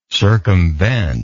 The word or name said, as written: circumventive